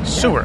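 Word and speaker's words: Seewer: People get on me for saying, that's how you say it, though. – Yeah. – Sewer. – Yeah. Yeah, okay. – Swiss kid, Swiss name.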